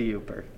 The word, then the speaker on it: Yooper: What do you think is the defining quality of a Yooper? Am I pronouncing that right? – Yeah, it's a Yooper.